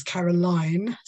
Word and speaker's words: Caroline: And now, obviously, you helped me a lot because your name, I would pronounce it Caroline, okay? But you put the E in brackets to make sure I pronounce it Carolyn, which is great.